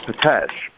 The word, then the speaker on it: Petes: So it's pronounced Potesh, but it's actually Peets is in spelling, P-E-T-E-S.